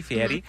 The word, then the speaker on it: Fieri: I say it correctly, Fieri.